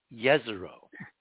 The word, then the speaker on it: Jezero: Not not yeah, except the J on Jezero is supposed to be pronounced according to our sources as a Y, Yezero.